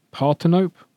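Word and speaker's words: Parthenope: And I'm also looking forward to, we've only got this one once as well, the pronunciation is Partenope.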